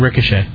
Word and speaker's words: ricochet: one of which rioted? Why am I mispronouncing that? And struck a 13-year-old boy in the leg. The boy was handcuffed and at gunpoint at the time. – And ricochet.